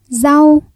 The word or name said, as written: rau